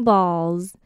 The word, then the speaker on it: balls: 首先第一段的最后一个字 balls 灯 balls 我会发成 z 的音。